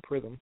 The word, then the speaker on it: Prythm: Chris Pritham, P is spelled as it sounds. See, now I have to spell it. P-R-Y-T-H-M, Pritham.